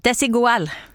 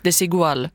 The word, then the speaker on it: Desigual: Desigual! – Desigual.